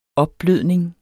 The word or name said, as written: opblødning